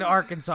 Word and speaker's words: Arkansas: No, it's fucking Arkansas. I was going to say you don't pronounce it the way it's spelled. – Oh, Arkansas.